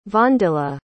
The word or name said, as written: vondila